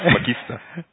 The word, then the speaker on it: Magister: Wie spricht man das aus? – Akademisch korrekt, Magister.